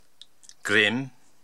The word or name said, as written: Grim